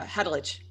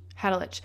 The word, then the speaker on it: Hadelich: is um, how do you say his last name? Hadalik. Ha- uh, Hadelich. Hadelich. Um, yeah.